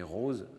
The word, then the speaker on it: rows: mais rose, R-O-W-S, qui veut dire rangée au pluriel, il n'y a rien à voir avec les fleurs, mais qui se trouve, par sa prononciation, être l'homophone, évidemment, d'un des noms les plus fréquents de fleurs, la rose. Comme stimulus contrôle, on peut présenter par exemple R-O-B-S,